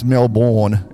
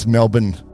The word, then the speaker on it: Melbourne: And it's not pronounced Melbourne, it's Melbourne.